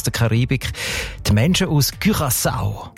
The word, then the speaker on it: Curaçao: Curaçao ist das Land, habe ich gelernt. Spricht man eben mit «ü» aus, weil Holländisch dort auch eine wichtige Sprache ist. Und im Holländischen ist das «ü» offenbar häufig als «ü», Curaçao.